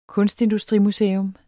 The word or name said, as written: kunstindustrimuseum